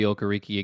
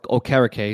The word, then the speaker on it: Okereke: Zaire Franklin is coming out this year and showing that he is a starting linebacker on any team outside of Bobby Okereke again, or Okereke, excuse me for my mispronunciation.